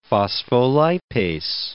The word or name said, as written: phospholipase